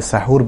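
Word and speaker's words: সাহুর (سحور): সাহরি মধ্যরাতের পরে করলে কোনো অসুবিধা নেই, তবে সাহরির ক্ষেত্রে, যেটাকে আরবিতে সাহুর বলা হয় মূলত, সাহার অথবা সাহুর, বাংলায় যদি আমরা সাহরি বলি, শব্দটার আরবি উচ্চারণ হচ্ছে সাহুর, এই সাহুর যদি কেউ মধ্যরাতের পরে দিন নেন অর্থাৎ পানাহার করেন তাহলে কোনো অসুবিধা নেই, যদি তিনি দেরি করেন সেটাই হচ্ছে সুন্নাহ।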